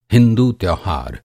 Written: त्यौहार

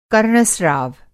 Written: कर्णस्राव

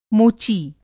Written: मोची

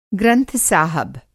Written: ग्रंथ-साहब

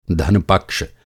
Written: धन

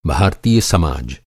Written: समाज

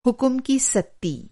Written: सत्ती

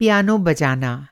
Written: पियानो

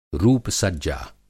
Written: रूप-सज्जा

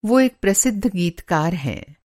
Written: गीतकार

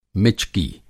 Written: मिचकी

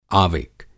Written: आविक

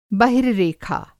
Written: बहिर्रेखा

